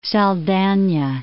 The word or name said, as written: Saldanha Bay